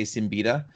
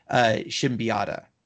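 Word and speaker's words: Siembieda: It's, if we were to say his name, we'd probably just say Simbita. But one time I got a chance to meet him and I was instructed that it's pronounced Shimbiata.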